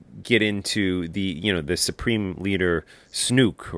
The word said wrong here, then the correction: Snoke: Am I saying that right? Snook? – Snoke. – Snoke. The Supreme Leader Snoke.